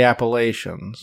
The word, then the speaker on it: Appalachia: The Appalachians. – It's Appalachia. – Appalachia. – People that live there call it Appalachia.